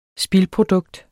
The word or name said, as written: spildprodukt